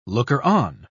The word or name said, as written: looker-on